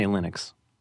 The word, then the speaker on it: Linux: And you pronounce it Linus, but you say Linux.